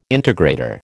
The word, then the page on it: integrator: American English